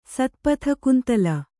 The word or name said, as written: ಸತ್ಪಥಕುಂತಲ